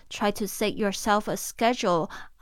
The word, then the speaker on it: schedule: 这边的 schedule，呃，它的发音是 schedule。这个 schedule 其实是英式发音哦，在美式发音比较有可能会听到 schedule，但是英式的口音都是讲 schedule，schedule，好像水饺，schedule。水饺。